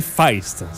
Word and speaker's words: Feist: la prononciation de ce nom-là est Feist. – C'est Feist.